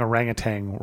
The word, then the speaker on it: orangutan: And you're also telling me that I've been saying orangutan wrong. – well, that's the native pronunciation? – Yeah, because it's orang, which means person. – Ah. – And then it's kind of truncated. Hutan means forest.